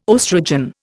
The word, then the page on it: oestrogen: Indian